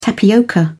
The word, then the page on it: tapioca: British English